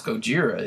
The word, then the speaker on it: Gojira: Now, G-O-J-I-R-A, that is Godzilla's real Japanese name. But it's not pronounced Gojira, is it? – It's Gojira, okay.